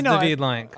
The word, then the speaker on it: David Lynch: Yeah, but I'm pretty sure it's David Lynch. – I think it's David Lynch.